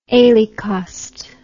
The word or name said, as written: alecost